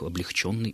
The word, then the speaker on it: облегченный: Конечно же, единственной правильной формой будет ударение на последний слог «облегчить», и ударение в причастиях и прилагательных «облегченный» и только «облегченный».